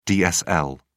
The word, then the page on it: dsl: British English